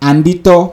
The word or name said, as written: Anditaw